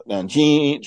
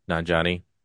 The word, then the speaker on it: Nanjiani: And the dad, played by Kumail Nanjiani, I can't even pronounce his last name. – Nanjiani.